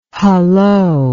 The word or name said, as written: hulloa